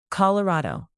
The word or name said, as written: colorado